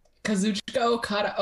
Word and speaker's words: Kazuchika Okada: Kazuchika Okada.